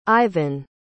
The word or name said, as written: ivan